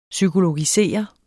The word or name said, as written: psykologisere